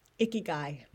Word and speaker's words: ikigai: Ikigai.